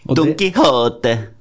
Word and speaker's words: Don Quijote: etter Don Quijote. Beklager spanskuttalen min. – Don Quijote.